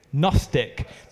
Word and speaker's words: Gnostic: It's not like I'm saying gnocchi. The Gnostic? Gnostic. There's no silent weird N. It's just Gnostic. No silent G. There is a silent G.